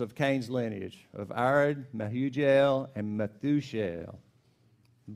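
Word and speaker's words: Mehujael: In verse 18, it says, Enoch had a son named Irad, and Irad became the father of Mahuel, and Mahujael. That's the same guy. I just pronounced it different.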